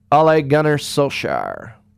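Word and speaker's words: Ole Gunnar Solskjær: Ole Gunnar Solskjaer.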